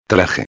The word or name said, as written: traje